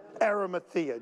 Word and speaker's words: Arimathea: Arimathea.